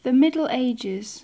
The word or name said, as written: the middle ages